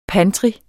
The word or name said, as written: pantry